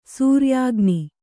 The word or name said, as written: ಸೂರ್ಯಾಗ್ನಿ